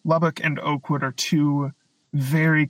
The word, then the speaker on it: Lubbock: The second syllable is a schwa, so it's just Lubbock.